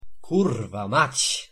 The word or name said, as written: kurwa mac